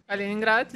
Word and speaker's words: Kaliningrad: It Kaliningrad? – Kaliningrad, yes. – Kaliningrad.